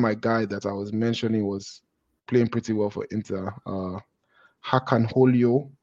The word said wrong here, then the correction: Çalhanoğlu: I might be butchering his name. – Çalhanoğlu. – Çalhanoğlu, yes.